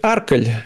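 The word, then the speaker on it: Arcole: По-местному надо было говорить «Арколь». Это все, ну, французов на последнем слоге ударения.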